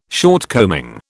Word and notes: shortcoming: British English

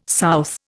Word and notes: south: Indian

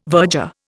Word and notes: virger: Indian

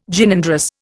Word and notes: Gynandrous: Indian

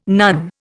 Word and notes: nun: Indian

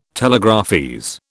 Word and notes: telegraphese: British English

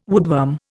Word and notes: woodworm: Indian

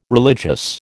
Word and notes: religious: American English

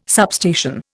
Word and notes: substation: Indian

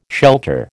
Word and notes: shelter: American English

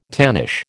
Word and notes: tannish: American English

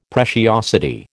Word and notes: preciosity: American English

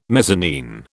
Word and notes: mezzanine: British English

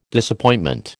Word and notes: Disappointment: American English

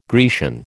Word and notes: Grecian: American English

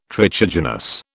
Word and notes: trichogenous: British English

